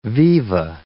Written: viva

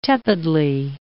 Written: tepidly

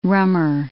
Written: rummer

